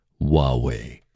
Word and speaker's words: Huawei: Huawei. – Huawei.